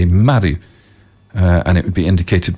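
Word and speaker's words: maru (丸): A circle in Japanese is pronounced maru.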